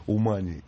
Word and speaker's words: Умань: Но, значит, по поводу Умани или Умани, здесь вот уже пришли два сообщения, поправляющие нас и говорящие, что нужно делать ударение в этом слове на первый слог.